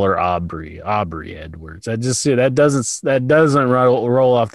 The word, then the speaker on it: Aubrey: And Aubrey, am I saying it right? Aubrey? – Yeah.